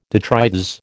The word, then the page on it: detritus: American English